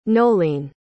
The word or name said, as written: nolene